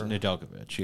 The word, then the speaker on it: Nedeljkovic: Nedeljkovic or yes, Nedeljkovic, yes.